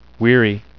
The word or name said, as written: weary